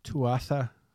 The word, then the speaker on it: Tuatha: Tuat? – Tuatha. – With an H? – No. – How do you spell it? – T-U-A-T-U-A.